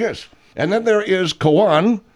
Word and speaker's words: Cohan: There's two ways of pronouncing it. There's the Irish Cohan. There's the Cohen, which is more European.